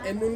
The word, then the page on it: mun: Ansus